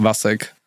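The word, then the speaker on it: Vašek: My like official legal first name is Václav. But everyone pronounces it as Vaclav, which I hate. So it's just Vasek.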